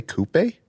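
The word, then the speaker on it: coupé: Did you say coupe? – That's how they say it in the UK. – Oh, okay. – See the accent. Threw me off a bit. See the accent over the E? – No, I hear you.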